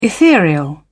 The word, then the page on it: ethereal: British English